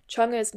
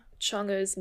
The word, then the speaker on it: Chang'e: I think it has to do with the fact that in Cantonese, Minnan and Hakka, which are all Chinese languages, Chang'e's name is pronounced, the second character is pronounced like Ngo. In Cantonese and Hakka, it's like Sung'o, and in Minnan, it's like Sheng'o, roughly something like that.